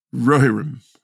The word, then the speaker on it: Rohirrim: Rohirrim.